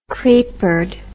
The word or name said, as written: creepered